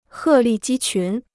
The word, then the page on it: 鹤立鸡群: hè lì jī qún